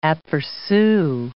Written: apercu

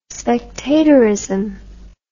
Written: spectatorism

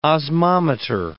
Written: osmometer

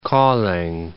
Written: Kolding

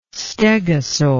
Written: stegosaur

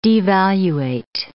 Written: devaluate

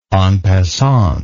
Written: en passant

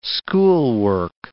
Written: schoolwork

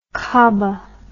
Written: kabah

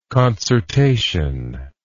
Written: concertation

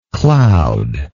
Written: cloudlike

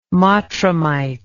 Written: mottramite

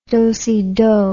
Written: dosedo

